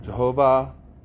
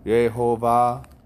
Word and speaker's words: Jehovah: Jehovah Jireh. It's pronounced Yehovah Jireh.